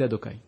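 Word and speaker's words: Adonaï: Lorsque tu vois un Yud à côté d'un He, à côté d'un Vav, à côté d'un He, Yudke Vavke, la façon de le prononcer, c'est Adokai.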